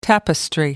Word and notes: tapestry: American English

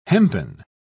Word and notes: hempen: British English

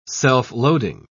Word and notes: self-loading: British English

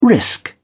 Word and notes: risk: British English

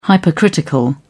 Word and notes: hypercritical: British English

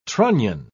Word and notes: trunnion: British English

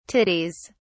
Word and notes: titties: British English

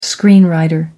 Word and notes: screenwriter: American English

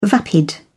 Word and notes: vapid: British English

